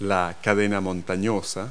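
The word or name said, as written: mountain range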